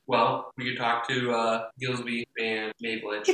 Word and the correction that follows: Gilby: It's pronounced Gilby. – Gilby. We'll talk to Gilby and May.